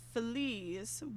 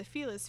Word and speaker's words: Feliz: So the two main pronunciations, Feliz, one from the Feliz family, and Feliz, meaning happy, are kind of, you know, some people want to be like, oh, it's pronounced this way. Technically, it has two pronunciations, and, you know, it doesn't really matter. I say Los Feliz because I like the happy.